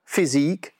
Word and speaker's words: physique: It is spelled as P-H-Y-S-I-Q-U-E. And you need to stress the second syllable, not physique, but physique.